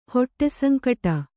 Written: ಹೊಟ್ಟೆಸಂಕಟ

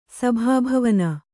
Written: ಸಭಾಭವನ

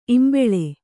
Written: ಈಂಬುಳಿ

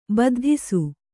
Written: ಬದ್ಧಿಸು

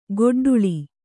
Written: ಗೊಡ್ಡುಳಿ

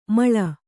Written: ಮಳ

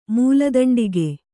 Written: ಮೂಲದಂಡಿಗೆ

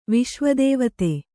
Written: ವಿಶ್ವದೇವತೆ

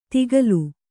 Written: ತಿಗಲು

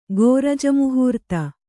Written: ಗೋರಜಮುಹೂರ್ತ

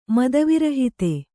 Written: ಮದವಿರಹಿತ

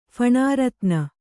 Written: ಫಣಾರತ್ನ

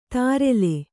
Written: ತಾರೆಲೆ

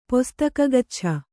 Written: ಪೊಸ್ತಕಗಚ್ಛ

